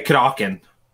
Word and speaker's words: Kraken: As we say, Kraken. It's like a bit of a different pronunciation.